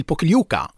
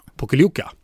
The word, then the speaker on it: Pokljuka: För du ska ju dit till, hur ska man uttala orten? – Till Pokljuka. – Pokljuka.